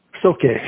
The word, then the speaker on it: Soke: Soke.